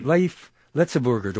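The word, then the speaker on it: Léif Lëtzebuerger: She would begin each broadcast, I gather, with the greeting, Leif Letzeburger. Do I say it correctly? – That's correct. Yes.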